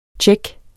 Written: check

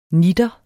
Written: nitter

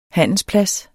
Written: handelsplads

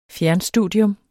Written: fjernstudium

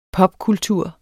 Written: pubkultur

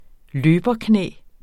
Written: løberknæ

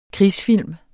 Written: krigsfilm

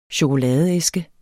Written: chokoladeæske

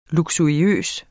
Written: luksuriøs

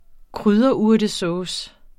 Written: krydderurtesauce

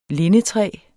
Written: lindetræ

